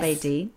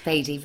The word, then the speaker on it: Bedi: And your second husband, I may be pronouncing it wrongly, Kabir Bedi. Yes. Bedi. Bedi, very good.